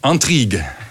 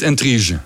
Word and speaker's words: intrigue: ja, weet je hoe de Fransen het uitspreken? Intrigue. Niet intrige.